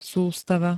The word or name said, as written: sustava